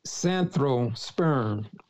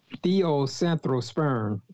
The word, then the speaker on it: Theosanthrosperm: and we call that Theo Theosanthrosperm. Theosanthrosperm. That's spelled T H E O S A N T H R O S P E R M.